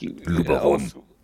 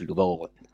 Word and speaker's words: Luberon: Da kann man was mit anfangen und dann gibt so zum Graveln tatsächlich eher den, ich sag jetzt mal Lubro, ich hoffe, es ist richtig ausgesprochen. – Auf Deutsch Luberon. – Genau, auf Deutsch Luberon.